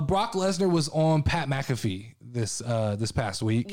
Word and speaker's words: McAfee: I think I say that right because I don't think it's McAfee. – It's McAfee. – Maybe it is. McAfee. Pat McAfee. – Like the software I think that's right.